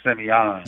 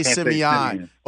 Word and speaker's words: Simeon: The guys at the barbershop on 51st Street will hurt me, if I don't say it like that, like Simeon. – You got to say Simeon, or you can't, yeah, or you can't Simeon. Go down Vincennes if you don't say it that way.